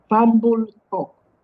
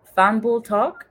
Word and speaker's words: Fambul Tok: How do you pronounce it again? – Fambul Tok. – Fambul Tok.